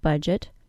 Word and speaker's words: budget: So while we can think of the D as being silent in the word budget, we can also think of it as knowing to pronounce the DGE spelling as a J sound, J.